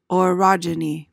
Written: orogeny

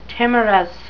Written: timorous

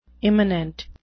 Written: immanent

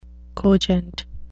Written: cogent

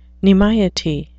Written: nimiety

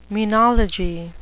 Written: menology

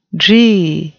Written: dree

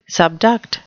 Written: subduct

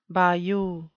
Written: bayou

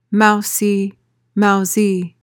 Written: mousy

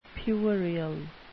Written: puerile

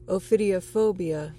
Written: ophidiophobia

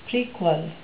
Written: prequel

